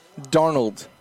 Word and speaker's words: Darnold: Darnold or Darno? – Darnold. – Darnold. – Thank you. – Yeah, that's it.